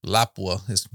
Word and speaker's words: Lapua: So if Lapua doesn't make brass for it, or Lapua, which is the proper way to say if they don't make brass,